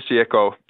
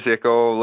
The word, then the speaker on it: Jecho: It is pronounced Jecho, like as if it was a Y for us Canadians. – It is Jecho. Yeah, it is Jecho.